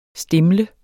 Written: stimle

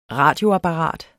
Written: RADIOAPPARAT

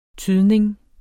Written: tydning

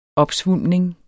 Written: opsvulmning

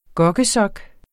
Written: gokkesok

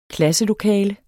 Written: klasselokale

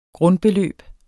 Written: grundbeløb

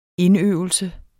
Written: indøvelse

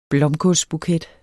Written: blomkålsbuket

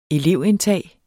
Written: elevindtag

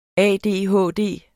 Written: ADHD

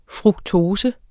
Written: fruktose

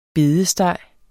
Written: bedesteg

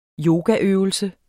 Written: yogaøvelse

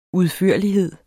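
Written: UDFØRLIGHED

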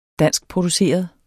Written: danskproduceret

